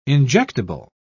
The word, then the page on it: injectable: British English